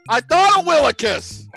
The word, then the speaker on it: Willekes: I don't know if I'm pronouncing this correctly, but it is Kenny Willekes. – Willekes? – God darn it. – Kenny Willekes. That's right.